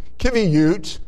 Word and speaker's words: Qiviut: Having just come from Alaska, we learned about this type of fabric or this type of yarn, Kiviut. That's spelled Q-I-V-I-U-T, Kiviut.